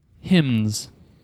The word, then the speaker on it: hymns: Your Final Jeopardy category is hymns. That's H Y M N S. That's right. Try to pronounce the N there.